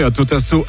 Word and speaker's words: Atotasso: Atotasso, A-Tota-So. Voilà, et ça s'écrit comme c'est dit.